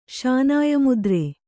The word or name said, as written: ಶಾನಾಯಮುದ್ರೆ